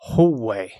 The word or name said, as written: hallway